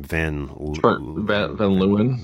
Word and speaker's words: Van Leeuwen: Van Leeuwen.